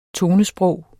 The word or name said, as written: tonesprog